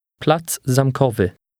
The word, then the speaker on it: Plac Zamkowy: Plac Zamkowy. Wy. Wy. Ko. Kowy. Zam. Zamkowy. Plac Zamkowy. – Notice how the two words run together.